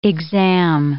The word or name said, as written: exam